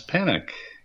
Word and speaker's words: Panek: My name is Thomas Panic. Not to be confused with Don't Panic, my last name is spelled P-A-N-E-K.